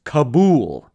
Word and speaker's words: Cabool: Kabul, C-A-B-O-O-L. So it is pronounced exactly like this.